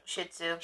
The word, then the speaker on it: Shih Tzu: Shih Tzu.